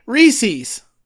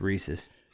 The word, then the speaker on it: Reese's: And you know what rhymes with feces? Reese's. Fucking tell me how to say Reese's. – That's incorrect. It's Reese's.